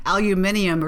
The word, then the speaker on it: aluminium: I mean, this isn't all just about investing in Canadian aluminum, right? – Aluminium or something is how they say it.